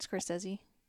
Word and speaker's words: Scorsese: Scorsese.